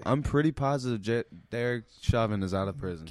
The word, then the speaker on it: Chauvin: I thought it was Chauvin.